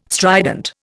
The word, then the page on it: strident: Indian